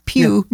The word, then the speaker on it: più: We have some news and updates for you, but the one that is più importante, isn't that funny? – Ooh. – Funny. I knew you'd think that. It's spelled P-I-U. Pew means more.